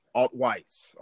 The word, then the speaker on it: Altwies: Tell us specifically how to pronounce your last name. – It's Altwies, sir. – Altwies. Okay, yes. That's not Weiss.